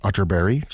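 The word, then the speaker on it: Atterberg: It's spelled Otterberg, but pronounced Otterberg, just in case you are Googling.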